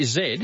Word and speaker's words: Zed: Oh, we all know this, Zed, because we say Zed, as in off your 3KZ, if you're not that well, Zed is pronounced Z in North America. – It is in the US, but not in Canada.